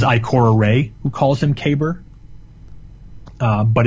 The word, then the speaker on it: Kabr: It's worth mentioning, though, in that mission, it's pronounced two different ways uh, Kaber and Kabir. Uh, the it's and it's Praedyth who pronounces it Kabir. Uh, but the true and one pronunciation is in fact Kaber.